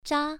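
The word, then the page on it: zha: tone 1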